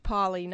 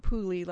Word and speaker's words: Pauly: And I would be remiss if I didn't say for John Pauly, his name is pronounced Pauly, not Pooley, like she said. It drives him crazy.